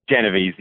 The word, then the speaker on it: Genovese: Do you know the pronunciation of her last name? – Genovese. Yep. – Kitty Genovese. Yeah.